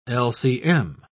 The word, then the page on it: lcm: British English